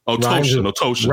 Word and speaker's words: Otoshin: Otoshin. – Rhymes with lotion.